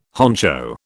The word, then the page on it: honcho: British English